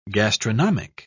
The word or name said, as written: gastronomic